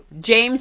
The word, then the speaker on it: Jame: J-A-M as in magnificent. – It's Jane spelled with an M. – Thank you. Jame spelled with an M.